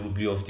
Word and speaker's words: Rublev: Turnuvamızın üçüncüsü ikinci yarı finalist Rublyov. E, Rublev diye yazılıyor, Rublyov diye okunuyor. Gökalp'ten öğrendim ben de.